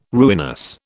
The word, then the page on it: ruinous: British English